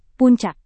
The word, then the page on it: puncak: Indonesian